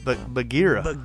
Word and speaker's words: Bagheera: It's Bagheera. – Bagheera finds him.